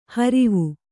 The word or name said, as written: ಹರುವೆ